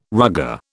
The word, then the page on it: rugger: British English